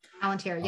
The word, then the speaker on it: Palantir: And it's about Palantir, if I'm pronouncing that right. – Palantir, yep.